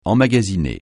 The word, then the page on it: emmagasiner: francais